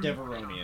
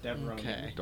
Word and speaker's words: Devaronian: It's a Devaronian. – Devaronian. – Devaronian. Yes. The devil-looking guys. Oh, yeah. – Yes, Devaronian.